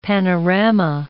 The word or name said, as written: panorama